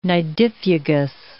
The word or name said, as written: nidifugous